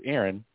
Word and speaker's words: Aaron: And anytime somebody asked me my name, I'd say my name is Aaron. And they'd say, oh, how do you spell that? And I would say A-A-R-O-N, like Hank Aaron.